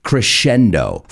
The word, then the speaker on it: crescendo: Y sé que conocéis esa palabra, pero la pronunciación es un poco diferente. Crescendo.